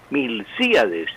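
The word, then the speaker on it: Milcíades: Sí, Julio César, el nombre de Longas Zapata es Milciades, con acento en la I de sí, Milciades.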